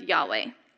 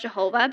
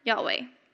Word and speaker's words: Yahweh: The closest we can make out in light of that, missing vowels, is that it's pronounced Yahweh. They used to think that it was pronounced Jehovah, but we now know that it's pronounced Yahweh.